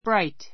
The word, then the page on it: bright: bráit; ブライト